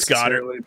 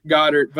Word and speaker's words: Goedert: It's Goedert. – But Goedert, okay. – I always tell people it's like the Jimmy Neutron dog. – Yeah, okay, there you go, Goedert.